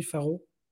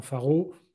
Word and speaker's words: Pharow: Et là, tu nous dis qu'en gros, Faro, d'ailleurs, comment ça s'écrit, Faro P-H-A-R-O-W.